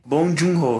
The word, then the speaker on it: Bong Joon-ho: Ele é dirigido pelo é Bo jung ho eu não sei como se pronuncia, tá? Bong Joon-ho. Bong Joon-ho, isso.